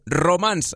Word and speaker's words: Romans: El Roman Carrillo, ja ho sabeu, Romans, escrit amb S, però es pronuncia Roman,